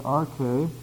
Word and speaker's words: arche: We have the word arche in the Greek, A-R-C-H, long E, and that is the word that translated beginning.